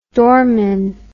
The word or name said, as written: dormin